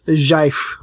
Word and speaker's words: GIF: It's Jif. Pronounced Jif.